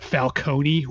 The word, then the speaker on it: Falcone: John Turturro will play Carmine Falcone, who is the, or I always said Falcone, but in Batman Begins, which the character is also in, they pronounce it Falcone, which I think is weird, but whatever pronunciation you prefer, that's the character he's playing.